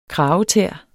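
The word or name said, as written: kragetæer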